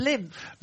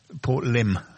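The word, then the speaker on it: Lympne: Lymph. I'll tell you, my lymph glands. – Or limb, as it's normally known.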